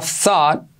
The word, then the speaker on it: thought: Muchos preguntáis sobre esta pronunciación, ¿vale? Thought, así es.